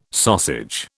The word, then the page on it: sausage: British English